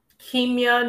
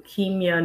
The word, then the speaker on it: Kimya: Kimya Nuru Dennis. Thank you for asking. So it's pronounced with a long E and then Nuru. Kimya Nuru.